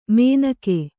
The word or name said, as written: ಮೇನಕೆ